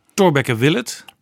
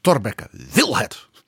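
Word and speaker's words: Thorbecke wil het: Ja, maar dat moet je anders uitspreken. Het is een citaat van een memorandum dat Remig Aarts in dat boek van iemand citeert. Het, je moet het zo zeggen, Torbekke wil het.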